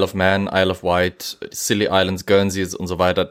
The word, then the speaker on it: Scilly: C-I-L-L. – Ah, Skilly. Achso. Mhm. Mhm. Eigentlich werden sogar silly ausgesprochen, deswegen sind die mhm. Silly Islands, was immer so ein bisschen ein Wortwitz im Englischen ist.